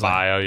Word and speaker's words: Adebayo: They have Bam Adebayo, however you pronounce it. – Adebayo, yeah. – Adebayo.